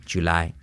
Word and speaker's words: July: No es una I, G-G-G, es una che, pero con voz. June, July, jacket.